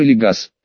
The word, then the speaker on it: Эригас: Моя память иногда чудит, и я могу перепутать какие-то звуки, но его название должно произноситься как Эригас. Может быть, Эригас. Эригас», — повторил я, чувствуя, что наконец-то взял нужный след.